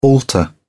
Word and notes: altar: British English

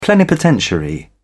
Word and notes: plenipotentiary: British English

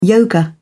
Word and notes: yoga: British English

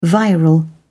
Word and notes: viral: British English

